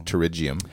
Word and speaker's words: pterygium: Also known as pterygium. Pronounced pterygium.